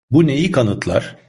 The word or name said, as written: neyi